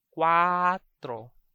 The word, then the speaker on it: cuatro: So that's the quick kuwa followed by the last syllable, tro. Cuatro, cuatro. So that would be 24, 24. But make sure to thrill your R. Always remember, in Cebuano, the R is always thrilled.